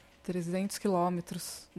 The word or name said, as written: trezentos quilometros